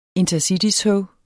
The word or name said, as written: intercitytog